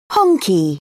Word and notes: honky: British English

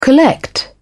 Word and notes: collect: British English

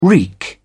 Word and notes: reek: British English

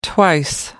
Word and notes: twice: American English

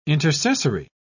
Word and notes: intercessory: British English

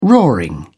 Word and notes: roaring: British English

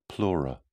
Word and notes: pleura: British English

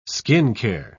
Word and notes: skincare: British English

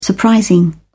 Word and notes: surprising: British English

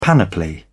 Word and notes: panoply: British English

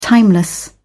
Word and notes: timeless: British English

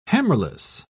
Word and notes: hammerless: British English

